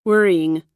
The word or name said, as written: wearying